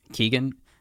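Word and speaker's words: Keoghan: How do we say his last name again? – Keegan? – Into Barry Keegan.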